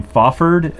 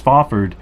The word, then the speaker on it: Fafhrd: I think you say his name Fofford, F-A-F-H-R-D, Fofford, Fofford and the Grey Mouser, the Lankmar stories, the two adventurers, the giant barbarian Fofford and the small thief, the Grey Mouser.